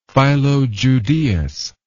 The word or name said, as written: Philo Judaeus